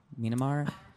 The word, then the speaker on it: Myanmar: myanmar myanmar thank you their um their genocide that happened in 2018 yeah.